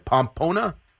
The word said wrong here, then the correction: Pompano: Pompano. – Pompano Beach. – Yeah, why do people have trouble pronouncing it? – Pompano, I don't know. It's Pompono. It's Pompano. That's okay.